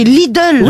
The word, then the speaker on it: Lidl: Il n'y a pas de O, c'est l'idole. – Oui, Lidl.